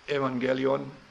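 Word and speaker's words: Evangelion (εὐαγγέλιον): Diesen Buchstaben kann man im Griechischen mit Ü aussprechen oder als V. Entweder F-Evangelium oder Evangelion.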